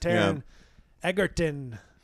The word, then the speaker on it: Taron Egerton: He's got Taron Egerton. Oh, I got the name right. – Yes.